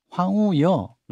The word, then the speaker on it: 황우여: 국민의힘은 비대위원장으로 황우여 이렇게 발음해야죠.